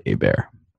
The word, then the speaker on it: Hebert: So, of course, it's C L A Y, and then the last name is H E B as in boy, E-R-T. It's pronounced, it's spelled like Hebert, pronounced like Hebert.